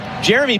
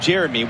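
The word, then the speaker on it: Yeremy: Because Jeremy Pino, that's how he says his name, Jeremy, with a hard J sound. And yet, any game you watch in the Premier League, any commentator is going to say Jeremy.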